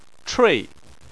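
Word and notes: chui: tone 4

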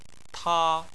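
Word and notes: ta: tone 1

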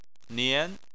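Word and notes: nian: tone 2

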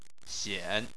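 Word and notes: xian: xian3; tone 3

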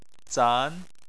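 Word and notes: zan: tone 2

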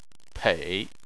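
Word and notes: pei: tone 3

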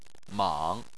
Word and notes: mang: 3

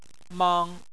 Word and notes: mang: 1

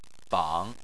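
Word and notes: bang: tone 3

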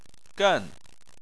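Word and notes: gen: tone 4

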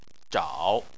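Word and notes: zhao: tone 3

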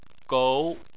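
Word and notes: gou: tone 2

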